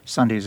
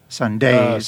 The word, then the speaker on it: Sundays: Ending a year of a month of Sundays, you put the accent on the second syllable, which is not the way Sundays is said. Did you know that? Sundays. – Sundays. – You did it on purpose? – I guess it rhymes with Malays, right? – Well, no, I know that, but you know, you're making a choice to say it not the way someone would normally say it.